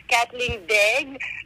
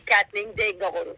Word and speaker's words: Katheleen Daigle: So in French, it's Katheleen Daigle, but everyone here calls me Katheleen Daigle, you know.